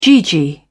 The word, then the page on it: gee gee: British English